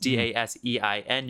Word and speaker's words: Dasein: That is Dasein. You can find him on SoundCloud. I can never say that part of the copy. – No, you said it right.